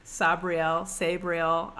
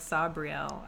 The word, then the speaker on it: Sabriel: So, yes, this book, Sabriel, I said... Sabriel, but I don't know. I kind of like Sabriel. I don't know. – Well, if you say Sabriel, you have to roll your R. – Sabriel.